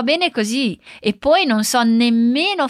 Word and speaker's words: così: De novo, agora atenção à pronúncia de sto, com S mudo, sto, e così, com ênfase no z.